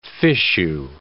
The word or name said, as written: fichu